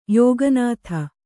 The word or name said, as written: ಯೋಗನಾಥ